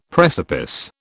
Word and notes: precipice: British English

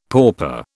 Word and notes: pauper: British English

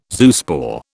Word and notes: zoospore: British English

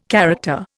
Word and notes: character: Indian

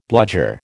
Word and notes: bludger: American English

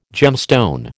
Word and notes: gemstone: American English

